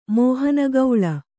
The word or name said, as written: ಮೋಹನಗೌಳ